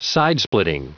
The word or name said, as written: sidesplitting